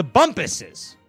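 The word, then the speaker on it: Bumpus: It rhymes with Krumpus, but it's not Krumpus. It's the Bumpuses.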